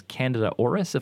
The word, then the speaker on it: Candida auris: And so the example you give is on this Candida auris, if I'm saying it right.